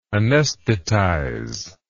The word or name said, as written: anaesthetize